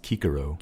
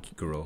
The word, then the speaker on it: Cicero: So Caesar would have been pronounced Kaiser. – Kaiser and Cicero would have been pronounced Kikero. – Kikero. Okay. – Which is very strange. Kikero does not sound Roman at all, but that's how they would have said it, supposedly.